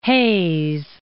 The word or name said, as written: Hays